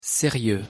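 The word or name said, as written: sérieux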